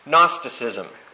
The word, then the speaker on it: Gnosticism: That G is silent, so we just pronounce it Gnosticism.